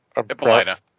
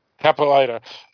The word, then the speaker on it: Hippolyta: Hippolyta. – Hippolyta.